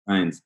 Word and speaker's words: Stinez: My nickname is Stinez. What? Uh, uh how do you spell that? Stinez.